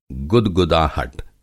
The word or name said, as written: गुदगुदाहट